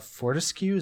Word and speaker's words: Fortescue: I noted that Fortescue, is that how you pronounce his name? – Yeah.